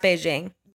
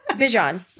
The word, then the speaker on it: Bijan: Not pronounced Beijing. – There's a TikTok of somebody asked him, they were like, what's the craziest way someone's ever pronounced your name? And he said Beijing.